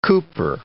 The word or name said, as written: cooper